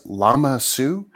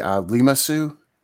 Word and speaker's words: Lama Su: It is Lama Su. I always want to mispronounce it and say Lima Su. That's not right.